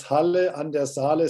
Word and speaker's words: Halle an der Saale: So you was born in 1953 in a place called Halle Saale. Have I pronounced that correctly? – Yeah, it's Halle an der Saale. Saale, it's a river.